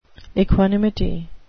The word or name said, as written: equanimity